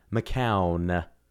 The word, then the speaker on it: McCown: Instagram's probably the one I'm more active on, and that's M as in Mary, Chase, like the verb, McCown.